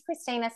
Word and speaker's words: Kristina: So if you wanted to go on to my podcast blog, which you can find, my name's Christina, spelt with a K.